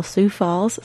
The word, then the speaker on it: Sioux Falls: I'm podcasting here today from beautiful Sioux Falls, South Dakota, and in case you don't know, that's spelled S-I-O-U-X, as in the Sioux, Lakota, Dakota, the Sioux Nations, Native Americans.